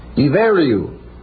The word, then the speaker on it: Ibheriu: One of the ancient names of Ireland is I-B-H-E-R-I-U. Pronounced I-B-E-R-I-U. Iveriu. But that's the way it's spelled.